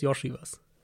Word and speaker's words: Iosivas: Ich weiß nicht, was genau seine Eltern, wo, wo da die Familienwurzeln liegen, aber ähm, ich habe es nachgeschaut und der, die, die Aussprache ist Yoshi was.